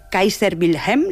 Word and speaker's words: Kaiser Wilhelm: que está sobre la iglesia Kaiser Wilhelm.